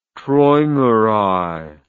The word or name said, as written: traumerei